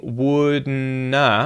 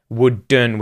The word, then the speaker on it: wouldn't: Wouldn't, because it's not wouldn, wouldn't with a hard D sound. It's actually wouldn't, wouldn't.